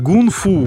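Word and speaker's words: гунг-фу (功夫): Только в борьбе такое или, допустим, в кунг-фу тоже? – Но поскольку я занимался еще ушу саньда, вам так повезло, я сейчас расскажу и про кунг-фу. Но правильно говорить, гунг-фу, внутренняя работа, это китайцы вообще очень к нам близки.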